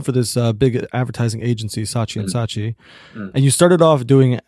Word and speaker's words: Saatchi & Saatchi: Did I pronounce that right? – Yeah. In the UK, it's Saatchi & Saatchi. I mean, it depends what side of the Atlantic you're on.